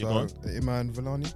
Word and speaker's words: Iman Vellani: Iman Vellani. – Yes. – Have I pronounced that correctly? – Yeah.